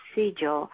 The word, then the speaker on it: sigil: And then I would also like to talk about, and I hope I'm saying it right, the signal? – Sigil. – Sigil, okay. – Sigil, S-I-G-I-L, sigil, yes.